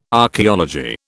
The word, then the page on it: Archaeology: British English